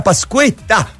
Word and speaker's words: Pasquetta: Buona Pasquetta! Oh, il diminutivo con l'accento. L'accento acuto sulla E, bravo!